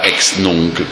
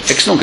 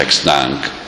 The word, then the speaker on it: ex nunc: Non si pronuncia ex nunc, come fa qualche annunciatore televisivo, perché non è inglese. Stranamente non è inglese, è latina.